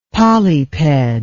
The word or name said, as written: polyped